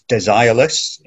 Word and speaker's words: Desireless: It's Desireless in mm-hmm. – English, if we say it like that.